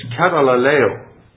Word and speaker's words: katalaleo: I'll just pronounce it for you for what I want to say. It's katalaleo.